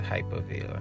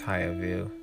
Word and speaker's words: Hyervale: Usually I get Hypervale and that's not cool, but yeah, it's Hyervale, H-Y-E-R, Higher Valley.